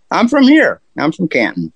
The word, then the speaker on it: Canton: And did I say it right? It's Canton, Ohio, where you are? – Yep. Canton, Ohio.